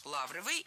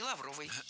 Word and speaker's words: лавровый: Но если кому-то кажется такой вариант странным, и если вы привыкли говорить лавровый, то переучиваться не надо, ведь норма ударения в этом слове двойная – лавровый и лавровый.